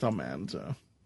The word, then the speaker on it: Almanzo: Well, Dean Butler flat out said that it's pronounced that way. Everybody I speak to says... pronounced Almanzo.